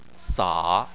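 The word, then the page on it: sa: tone 3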